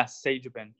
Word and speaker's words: Sagebin: His name is Leo, and correct me on your last name, is it Sagebin? Is that how you say it? – Yeah, Sagebin, yep.